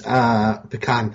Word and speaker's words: pecan: My favorite is pecan pie. – And thank you for pronouncing it correctly.